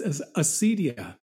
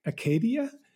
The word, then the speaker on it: acedia: Yes, you were correct in the first instance. At least that's the way I pronounce it, Pandora. Yes, acedia. In the ancient world, it was acadia.